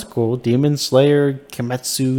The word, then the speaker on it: Kimetsu: Yeah, Kimetsu.